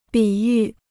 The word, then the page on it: 比喻: bǐ yù